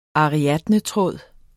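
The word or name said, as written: ARIADNETRÅDE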